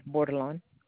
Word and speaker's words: Bordelon: B as in boy, O-R-D-E-L-O-N, Bordelon.